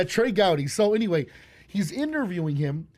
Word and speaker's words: Gowdy: and so Ted, Ted uh, Gowdy or Groudy? Trey Gowdy. Trey, You're saying it right. Trey Gowdy.